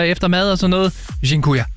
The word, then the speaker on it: Dziękuję: Og en af dem, som jeg har brugt rigtig meget, det er det, der hedder, undskyld min udtale, Dziękuję. – Dziękuję? – Dziękuję.